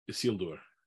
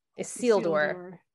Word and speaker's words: Isildur: Isildur. – Isildur.